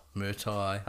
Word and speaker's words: Murtaugh: Is it Murtaugh? – Murtaugh, aye.